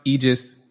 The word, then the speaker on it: Aegis: It's pronounced Aegis Malpractice Solutions, but it's spelled A-E-G-I-S.